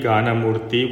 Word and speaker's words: Ganamurti: Kanakangi, Ratnangi, Ganamurti,